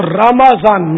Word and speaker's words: رمضان: اب رمضان بولتے ہیں تو چلے وہ دعا تو عربی کا وہ قرآنی تلفظ تو مخرج تو رمضان تو مشکل ہو جائے گا، مگر وہ رمضان میم پر زبر جو آسان ہے وہ تو اپنا لیا جائے.